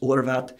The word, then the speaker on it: Horvath: Like, my last name is Horvath, and in Hungary that's pronounced Horvath.